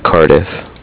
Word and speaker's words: Cardiff: With Cardiff. – Spell that. – CARDIFF. – Okay, just like it sounds.